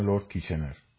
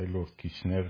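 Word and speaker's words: Kitchener: خیلی قوی بود ارتش بریتانیا اون دوره خب این اسکوید فرمانده ارتش کی بود لورد کیشنر خب بعضی میگن لورد کیشنر و لورد کیشنر تلفظ صحیح تریه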